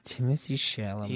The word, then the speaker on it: Timothée Chalamet: Timothée Chalamet. I think that's how you say his name. – Timothée Chalamet.